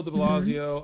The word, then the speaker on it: de Blasio: Mayor Bill how you say it, de Blasio? Bill de Blasio mm-hmm.